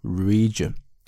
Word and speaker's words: region: Arab 就是阿拉伯啊，这个 region 表示的意思呢是区域，注意它的发音啊，中间发的是这个长元音啊，region。